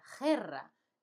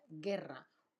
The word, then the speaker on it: guerra: Acordaos que guerra se escribe con G-U, no G-E, eso sería guerra. Queremos decir guerra, que es G-U, pero la U no se pronuncia, guerra.